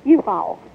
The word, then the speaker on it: U-Haul: Well, they may be pronouncing it, but it's just Uhaul, like you haul something. U-H-A-U-L.